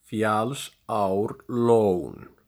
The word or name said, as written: Fjallsárlón